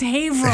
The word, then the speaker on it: Haverhill: And please pronounce Haverhill properly. That's Haverhill. – Haverhill, yeah.